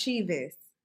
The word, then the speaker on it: Cheaves: You was almost right. It's Cheaves.